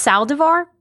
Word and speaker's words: Saldivar: So it's Saldivar, but it's Spanish from Spain. And so I think they pronounce it like the, and they spell it with a Z. So it's Saldivar or something is how they would say Saldivar or something.